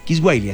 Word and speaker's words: Kiswahili: Kiswaik. Bueno, creo que así se pronuncia. Kiswahili, así día de la lengua Kiswahili.